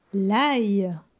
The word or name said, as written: L'ail